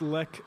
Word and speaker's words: Lech: Yeah, it's Lech, like it rhymes with tech, the abbreviation for technology.